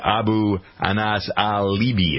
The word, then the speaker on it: Abu Anas al-Libi: officials said yesterday that interrogators are going to question the Al-Qaeda operative aboard the American warship where he has been moved, Abu Anas al-Libi. Am I pronouncing his name right? – Sure.